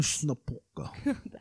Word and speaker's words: Cluj-Napoca: Cluj-Napoca. I want to make sure I say that right. It sounds like. – Cluj-Napoca.